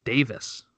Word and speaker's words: Davies: And I'm gonna just say Davies. – That's how they always say it for Alphonso Davies. And it's because apparently over in England what I heard was that they pronounce Davies as Davis.